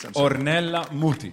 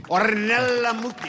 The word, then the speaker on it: Ornella Muti: Ornella Muti. – Ornella Muti.